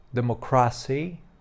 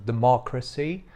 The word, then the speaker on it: democracy: Albo mówi na przykład democracy zamiast democracy.